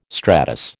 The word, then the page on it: stratus: American English